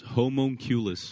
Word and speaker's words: homunculus: This is Roger the Humunculus It's pronounced homunculus.